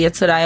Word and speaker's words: Yatsura: Urusei Atsura, falei certo? falou, não sei, Yatsura